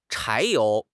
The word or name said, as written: 柴油